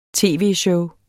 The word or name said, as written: tv-show